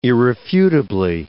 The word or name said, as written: irrefutably